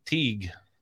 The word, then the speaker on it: Teague: Do you pronounce it like she does? – Teague.